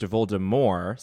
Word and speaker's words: Voldemort: Rowling abides by this, it's pronounced Voldemort, silent T.